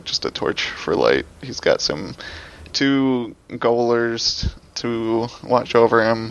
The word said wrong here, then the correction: gaolers: It's jailers. It's jailers. – Oh, gaolers. – It's jail. It's jail. It's pronounced jail.